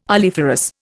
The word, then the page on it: oleiferous: Indian